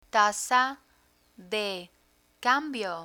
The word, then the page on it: tasa de cambio: Spanish